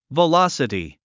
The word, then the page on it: velocity: vəlάsəti; ヴァラサティ